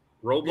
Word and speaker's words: Roblox: Roblox, yeah, how do you say it? – Roblox.